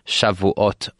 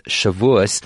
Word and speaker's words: Shavuos: It's Thursday afternoon, fresh thinking time, as we prepare ourselves not only for a Shabbos this week, but for a big event, the festival of Shavuot, or as I like to pronounce it, as many people like to pronounce it, Shavuos.